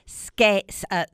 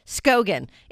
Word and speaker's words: Skagen: I've got Navi Mendoza, Mo Brossette in studio with me, Ashton Slovak, and Mallory Skagen. Don't tell me. I've got it written down here. Skogan. It's an A, but it's pronounced like an O.